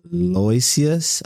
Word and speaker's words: Aloysius: It's A-L-O-Y-S-I-U-S. – Let me see. – Aloysius. – Yeah, Aloysius. Yeah, Aloysius J.